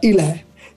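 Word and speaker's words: Ile (إله): Poi Dio in arabo si pronuncia Ile. Da qui già avete lo, il primo spunto. Per dire Dio in senso generico in arabo si dice Ile, mentre Allah è il nome personale della divinità del Corano.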